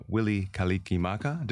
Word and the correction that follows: Mele Kalikimaka: Did I say that right? – Well, I don't know. I'm not Hawaiian, but, okay, but I know Mele Kalikimaka, but yeah.